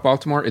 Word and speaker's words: Baltimore: Don't pronounce the T. Doesn't it? – Is that true? – Yeah, they all say Baltimore.